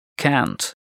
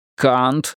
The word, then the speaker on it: can't: Тебе надо сделать звук «а» более плоским. Ты, считай, не живешь по-настоящему, пока не научился произносить гласные по-американски. Скажи «can't». – «Can't». – «Нет, can't». Будто козел заблеял.